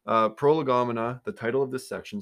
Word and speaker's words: prolegomena: I have trouble with this word, prolegomena. I think I said that right. – Yeah. – Pro, lego, mina, whatever, say it however you want.